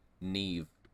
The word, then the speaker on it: Niamh: The like, name Neve. – Neve. – Which is spelled N-I-A-M-H. Neve.